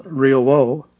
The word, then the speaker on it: Rioboo: The two new announcers are Daniel Riobo, R-I-O-B-O-O, accent on the middle O, and Alba Cuellar, A-L-B-A-C-U-E-L-L-A-R.